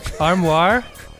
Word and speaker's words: armoire: Did I pronounce both words wrong? – Yes. – Armoire. Amour. Amour.